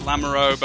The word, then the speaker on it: Lamoureaux: Did I say that right? – Nah, 5 years working together, it's Lamoureaux, but it's okay.